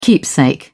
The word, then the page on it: keepsake: British English